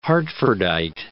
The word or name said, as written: Hartfordite